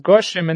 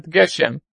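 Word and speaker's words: Goshem (גשם): And therefore, he's mechadesh, that when it comes to Mashiva Ruach, Umorid HaGoshem, that it should be pronounced as Goshem and not Geshem.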